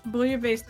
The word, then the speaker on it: Bouillabaisse: Bouillabaisse? – No, that's right. Bouillabaisse is how you pronounce it.